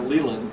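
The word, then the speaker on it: Leyland: Of course, manager Jim Leland is how you pronounce that, Leland.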